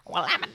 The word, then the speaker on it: Willamette: Willamette, damn it.